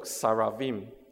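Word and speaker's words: Saravim (שְׂרָפִים): 火蛇在原文里面就读 Saravim，因为读音好像撒拉弗，所以也有圣经学者他解读火蛇就是火的天使。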